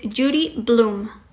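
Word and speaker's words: Judy Blume: Y bueno, el nombre de dicha escritora es Judy Bloom, que se escribe J-U-D-Y Bloom. B de bueno, L-U-M-E. Judy Bloom.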